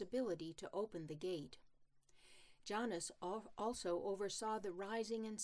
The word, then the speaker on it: Janus: The month of January was named for the Roman god Janus, or Janus, to give it the Latin pronunciation.